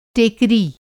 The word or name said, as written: टेकरी